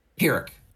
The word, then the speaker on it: Pyrrhic: And I didn't want to give you a Pyrrhic victory. – Pyrrhic.